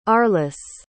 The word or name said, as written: arlis